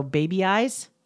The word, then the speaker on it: Babeyes: I saw an advertisement yesterday for a thing called, it's spelled Babe, B-A-B-E-Y-E-S. So baby eyes.